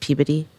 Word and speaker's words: Peabody: Barb, before you answer, if Greg is from Massachusetts, it's pronounced Peabody.